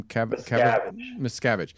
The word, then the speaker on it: Miscavige: Miscavige.